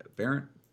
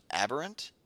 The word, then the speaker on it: Aberrant: I used to play where this guy came from, which was Delver of Secrets, but we're talking about the Aberrant Researcher. – It's pronounced Aberrant. – Aberrant? Yeah, I've goofed that one.